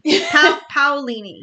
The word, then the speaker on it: Paolini: While I look up how to pronounce the author's name. – Christopher Paolini. – There you go. Okay. – Or Paolini. One of those two. Yeah. – I'm like, I can't pronounce his name. Paolini. Yes. Yeah.